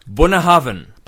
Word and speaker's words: Bunahabhain: It's Bunahabhain. – Bunahabhain. – Bunahabhain. – Buna. – Yeah, but the correct pronunciation is Bunahavn. – Bunahavn. Easy, right? – Bunahavn. Once you know it, it's easy.